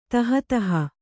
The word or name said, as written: ತಹತಹ